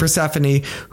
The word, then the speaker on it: Persephone: Persephone.